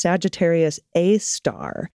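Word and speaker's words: Sagittarius A*: The black hole's name is Sagittarius A, with an asterisk after the A, and pronounced Sagittarius A star, even though that's not a star.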